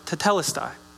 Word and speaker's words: tetelestai: The word here in Greek is pronounced tetelestai. Tetelestai.